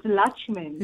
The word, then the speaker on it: Lutchman: It's Luchman.